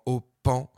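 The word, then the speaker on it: Ô Paon: Et puis juste avant, Au Pan, ça s'écrit O avec un accent circonflexe, P-A-O-N, comme un pan.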